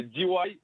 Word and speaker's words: D.Y.: Bonjour, bonjour, comme chaque dimanche, on est ensemble et on est avec nos chanteurs préférés, avec notamment MC D.I. Dis-moi, j'ai bien prononcé? – C'est D.Y.